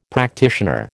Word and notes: practitioner: American English

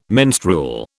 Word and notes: menstrual: British English

